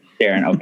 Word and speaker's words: Deron: So we have Russell Westbrook, Chris Paul, LeBron James, Steve Nash, Deron Williams, or Darren. I don't know how you pronounce it. One of the two. Whatever. – Darren, okay.